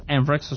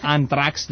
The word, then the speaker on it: Anthrax: No, perché Anthrax, che si pronuncia appunto Anthrax, ho scoperto oggi, sono sempre chiamati Anthrax da ignorante. – Beh, da italiano ignorante.